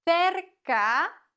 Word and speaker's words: cerca: Cerca. Cerca. C vor E und I wird gelispelt. Und C vor A, O und U wird wie ein K ausgesprochen. Cerca de la cafetería nueva.